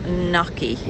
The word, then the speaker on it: gnocchi: I obviously can't check it because I'm driving, but I am pretty confident that she's right and it is gnocchi. – Gnocchi. – The G is silent.